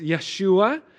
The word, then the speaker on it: Yeshua: The Hebrew pronounce it as Yeshua, and the name in Hebrew means the Lord saves, or the Lord is salvation.